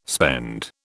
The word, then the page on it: spend: British English